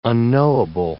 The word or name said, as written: unknowable